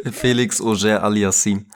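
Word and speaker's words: Félix Auger-Aliassime: Gerade jetzt mit dieser neuen Welle von Carlos Alcaraz, Yannick Sinner, Kaspar Rüth, ähm, auch ein Felix, Felix Auger Alissami. Ich kann das aber nicht aussprechen. – Felix Auger Aliassim. Danke. Perfekt.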